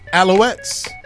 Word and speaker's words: Alouettes: In 2018, he also played for the Montreal Alou- uh, let me say it right, Alouettes.